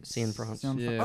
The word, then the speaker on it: Cianfrance: Derek Cianfrance? – Cianfrance.